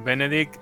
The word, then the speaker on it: Benedict: Ostras, es que Benedict Cumberbatch. – David. – Este Benedict Cumberbatch. – Efectivamente, hoy lo he dicho bien. Benedict, ahí hay que pronunciar la T al final.